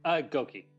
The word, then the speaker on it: Gokee: Gokee, yeah.